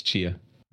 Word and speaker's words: Chia: Is it just Chia or is it? – It's Chia.